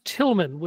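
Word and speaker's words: Tilghman: Apparently it's pronounced Tillman, which is annoying. – That's right, yeah. – Because it's spelled in, like, the most obnoxious way possible.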